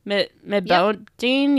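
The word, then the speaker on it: Mbodiène: M-B-O-D-I-E-N-E. Medbodine?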